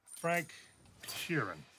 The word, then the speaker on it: Frank Sheeran: Frank Sheeran. I said that right? Yeah, you said right.